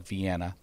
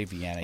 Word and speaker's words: Vienna: You don't say, it's spelled like Vienna, but you don't say Vienna. You have to say Vienna in Maine, unless you're from away, if you say it correctly.